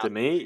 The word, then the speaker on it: Demy: Demy, yeah.